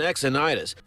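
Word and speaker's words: Xynides: I think that's how you pronounce his last name. It's X Y N I D E S. Now, what did your uncle? What you got a pronunciation? Dylan Exonitis. Dylan Exonitis.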